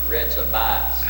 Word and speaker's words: Rechabites: Somebody's got to say Rechabites. Rechabites. – Rechabites. – No, it's Rechabites. – Oh, yeah, that fourth syllable.